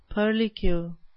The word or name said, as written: purlicue